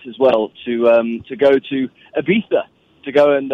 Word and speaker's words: Ibiza: Ibiza? – Yes. – So Ibiza, I don't know who Addison is, but Addison had a bachelor party, and you went to Ibiza.